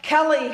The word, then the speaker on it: Kelly: Kelly has an E-Y at the end, which I hold is the correct way to spell my name.